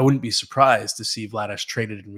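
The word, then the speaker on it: Vladař: It's Dan Vladash. That's how you pronounce his last name, right? – Yes. – In Calgary. It's not Vladder, and he did list Vladash as a possibility for a trade.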